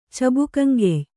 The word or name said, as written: ಚಬುಕಂಗೆಯ್